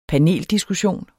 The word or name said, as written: PANELDISKUSSION